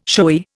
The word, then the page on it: showy: Indian